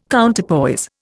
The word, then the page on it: Counterpoise: Indian